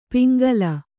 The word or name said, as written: ಪಿಂಗಲ